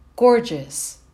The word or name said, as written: gorgeous